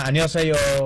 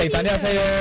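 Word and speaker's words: annyeonghaseyo (안녕하세요): Hola, annyeonghaseyo. Pero sí canta annyeonghaseyo. Como cantadito, ¿no? – Ajá, cantadito.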